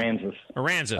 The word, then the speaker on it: Aransas: All right, so Port Aransas, am I saying that right? Aransas, Port Aransas. Is that what it's called? – Aransas.